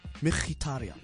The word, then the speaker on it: Mkhitaryan: This is how you pronounce it, guys. Henrik Mkhitaryan. That's how you pronounce that name.